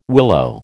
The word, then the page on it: willow: American English